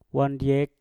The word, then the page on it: wandyek: mpur